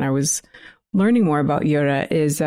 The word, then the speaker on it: Yodha: Um, and the word is Yoda. It's spelled Y O D H A, but it's pronounced the same.